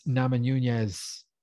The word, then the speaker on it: Namajunas: Rose Namajunas versus Carla Esparza. Is that pronounced correctly? – Sounds about right.